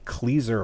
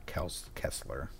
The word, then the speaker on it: Kleiser: And the director's name is like Kleiser or Kleiser, not Kessler. – Oh, I thought it was K-L-E-I-S-E-R.